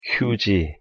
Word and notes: 휴지: Korean

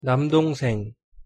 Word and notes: 남동생: Korean

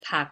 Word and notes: 밖: Korean

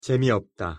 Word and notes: 재미없다: Korean

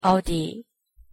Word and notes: 어디: Korean

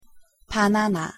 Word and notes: 바나나: Korean

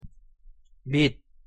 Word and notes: 밑: Korean

